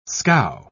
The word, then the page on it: scow: British English